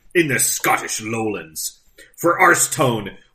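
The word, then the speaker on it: Aerstone: William Grant and Sons, Arstone. I think that's what it says. Arstone. Yeah, I'll go with that. – Shift your pronunciation. Just there. – A-E-R-S. Arstone. Never Arstone.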